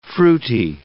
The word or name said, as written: fruity